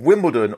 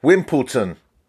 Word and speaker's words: Wimbledon: Now, of course, today is the start of Wimbledon, or as John put in his book, as the Americans call it, Wimpleton.